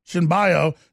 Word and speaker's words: Cenobio: Cinoboa? C-E-N-O-B-I-O, Cinbio, didn't stop, and the officer reportedly initiated a brief chase.